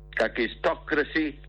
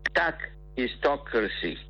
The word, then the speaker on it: kakistocracy: Kakistocracy. Let me pronounce it clearly. Kakistocracy.